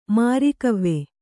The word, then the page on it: ಮರಗಿವಿ: maragivi